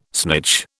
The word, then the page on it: snitch: British English